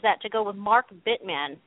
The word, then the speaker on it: Bittman: That's how you say his name, right? – I believe so, yes. – What is it spelled? Bittman? Bittman? – Yes, Bittman.